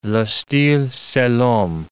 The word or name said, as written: le style, c'est l'homme